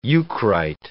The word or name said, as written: eucrite